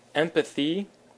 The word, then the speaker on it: empathy: And then empathy is our last one, empathy, with the emphasis on the M, empathy.